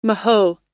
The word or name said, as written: mahoe